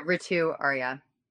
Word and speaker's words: Ritu Arya: tell, how do we discuss, again, say her name, Ritu Arya? Am I saying that correctly? – Yeah, Ritu Arya.